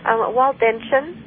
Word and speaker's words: Waldensian: Waldensian.